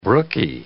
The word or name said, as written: brookie